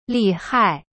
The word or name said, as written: 厉害